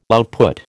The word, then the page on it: output: American English